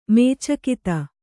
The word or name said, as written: ಮೇಚಕತೆ